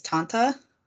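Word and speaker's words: tante: So how they say it is Tanta. Tanta means aunt.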